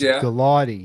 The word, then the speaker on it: Gulati: It's a hard G, so it's Gulati.